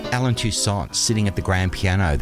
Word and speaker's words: Toussaint: And in case you were wondering, I'm pronouncing his name Toussaint because that's the way he pronounced it. It's spelt T-O-U-S-S-A-I-N-T, but because of the French influence in New Orleans, it's pronounced Toussaint.